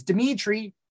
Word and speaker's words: Dimithri: I mean, Paul, your name is pretty straightforward, but as someone who has a silent H in their name, mm-hmm. I see myself, you know, in Madison. Like it's Dimitri. It's three I's and an H, but not where you think.